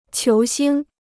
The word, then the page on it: 球星: qiú xīng